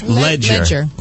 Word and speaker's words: Ledger: Ledger.